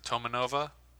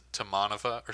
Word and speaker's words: Tomanova: But it's funny because, and I'm pretty sure it is pronounced Tomanova, but I think Pete says Tomanova or something like that. – Oh, yeah. It. – He puts, like, the accent on the second syllable, which is a very American thing.